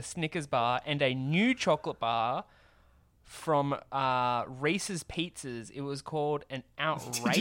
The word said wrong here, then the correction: Reese's Pieces: Did you just say Reese's Pizza? – Reese's Pizzas. Reese's Pizzas. I can't say it properly. – Say it again. You can't. – I can't. – Say it again. Wait, Reese's wait. Just say pieces.